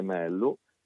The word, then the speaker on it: Mello: Sì, Sofia De Mello, diciamo che la doppia L del nome di Sofia in portoghese non si legge come lo spagnolo. – Come lo spagnolo, certo. – Si legge come Sofia De Mello.